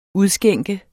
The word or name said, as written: udskænke